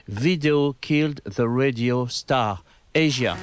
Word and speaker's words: Asia: Je veux parler de Asia, ou pour les gens qui ne prononcent pas bien l'anglais, Asia.